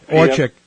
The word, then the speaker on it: Orchic: Am I pronouncing that properly? – Orchic.